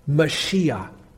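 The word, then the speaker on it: Mashiach (משיח): It's pronounced Mashiach.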